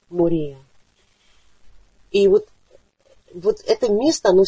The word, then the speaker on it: Мория: Кстати, обратите внимание на ударение. Оно почти в русском, ну как нам хочется сказать Мария. Но это О, оно должно звучать. Мория. Хотя это безударное. Мория.